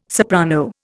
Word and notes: soprano: Indian

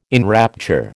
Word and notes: enrapture: American English

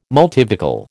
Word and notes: multivocal: American English

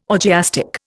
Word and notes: orgiastic: Indian

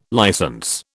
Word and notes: license: British English

